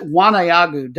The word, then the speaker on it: Waneagu: Number B, Solomon Warriors versus number 3, going to pronounce it Wanayagu, W-A-N-E-A-G-U, United, Wanayagu United.